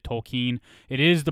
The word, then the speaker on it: Tolkien: So when we hear people say Tolkien, it sounds bad, and we all get annoyed by it. But guys, that is the proper way to pronounce it, so don't freak out.